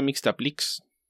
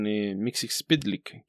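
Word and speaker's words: Mxyzptlk: Cuando yo era joven decía Mixtaplix. Y después supe que no era así porque vi varios comentarios que hacían rimas y cosas por el estilo con Mixxpitlick, que es la forma en la que lo digo actualmente.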